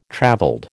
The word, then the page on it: travelled: American English